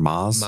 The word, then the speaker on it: Mads: Actually, do you know that's apparently pronounced, like, Mass or Maz? – Maz.